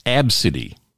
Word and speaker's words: Abcde: She said people go with obesity, ab city, or just alphabet. And now she's corrected them all because they're all wrong. She said her name is actually pronounced Abcde. Abcdy, yeah.